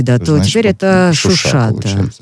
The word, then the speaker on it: Шуша: Тут вот очень важно, да, правильно ставить ударение, потому что оно имеет такой политический оттенок, поскольку азербайджанцы называют этот город Шуша, а армяне Шуши.